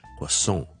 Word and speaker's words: croissant: 咁呢个系我哋讲英文嘅人呢想扮有少少似法文嘅音嘅时候呢会读嘅音，就係 croissant, croissant, croissant。例句 can I have a croissant please? 如果美式英文呢通常呢啲咁嘅来自法文嘅字呢就会将个重音放喺后面，就会变咗系 croissant, croissant, croissant。